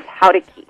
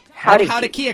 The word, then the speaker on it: Jaurequi: So my mom decided to give me a variation of her maiden name, so my middle name is Howdeki. – Howdeki? – Howdeki a car? – Not like a car, like Howdeki. It's spelled like J-A-U-R-E-Q-U-I.